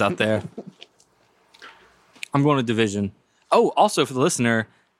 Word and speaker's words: DVSN: it's spelled DVSN. – That's me. – It's pronounced Division.